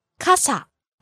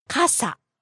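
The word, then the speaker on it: 傘: Kasa. – And then we also learned umbrella department. – Kasa売り場. – Okay, the word umbrella is kasa. It goes from high to low, ka-sa.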